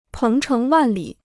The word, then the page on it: 鹏程万里: péng chéng wàn lǐ